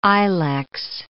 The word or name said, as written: ilex